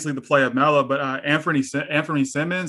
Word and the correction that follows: Simons: is that you say his name? Simons, yep. Simons, yeah.